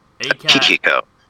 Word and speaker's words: Akihiko: Was it Akiho? – Let me look at it to make sure I'm pretty. Akihiko. – Akihiko. – A-K-I-H-I-K-O.